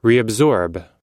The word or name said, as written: reabsorb